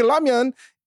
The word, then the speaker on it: ramyeon: I'd fucking be like, "Fuck you," because that's the tr- correct way to say ramyeon in Korean. You say an L more of an R.